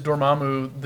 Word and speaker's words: Dormammu: Well, we kind of touched on it a couple times, but in discussion, is Dormammu the MCU Galactus? – He said it right.